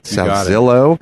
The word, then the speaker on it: Salzillo: Salzillo.